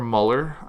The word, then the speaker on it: Mueller: Mueller III, or Mueller, I guess is actually how you pronounce it, the special counsel examining Russian interference in the 2016 presidential election.